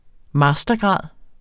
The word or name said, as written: mastergrad